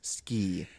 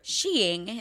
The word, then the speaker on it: ski: Or sheing, as it's properly pronounced. – I mean, it may have been a thing from the original that's like, is like the Scandinavian pronunciation.